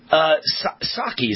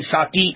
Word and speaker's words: Psaki: All right, so Jen Psaki is how you pronounce it, right? Jen Psaki, the spokesman for the White House.